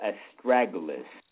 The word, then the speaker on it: astragalus: There's a herb called astragalus. Let me spell it for you. A-S-T-R-A-G-A-L-U-S.